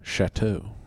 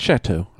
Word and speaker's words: Chateau: And I'm lucky that I know what Chateau is, how it's pronounced, because it looks like Chatea. To you? – Yeah. – It's Chateau. I know that.